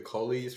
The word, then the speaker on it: Colles: B, the distal radius, also known as the Colles fracture. – Yeah. – Am I saying that right? – Yeah, you're right. Yeah, Colles fracture.